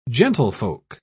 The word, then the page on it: gentlefolk: British English